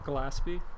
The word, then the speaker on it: Gillespie: Bill, how do you say your last name so I don't mess it up like an idiot? – Gillespie. – Gillespie.